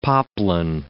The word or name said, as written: poplin